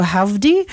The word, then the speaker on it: Hovvdy: It's spelled H-O-V-V-D-Y, but it looks like howdy. So howdy, try, try, try.